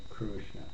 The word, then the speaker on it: Krishna: how should you pronounce Krishna? Is it Krishna or Krishna or Krishna? You know, different parts of India, different scholars even will, slightly different. In strict Sanskrit, it's something like Krishna.